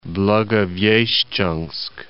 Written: Blagoveshchensk

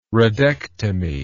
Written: radectomy

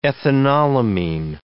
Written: ethanolamine

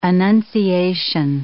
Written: Annunciation

